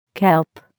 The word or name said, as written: kelp